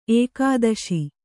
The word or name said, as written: ಏಕಾದಶ